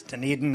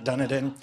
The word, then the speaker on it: Dunedin: It's pronounced Dunedin. No. All right, no. It's Dunedin, not Dunedin.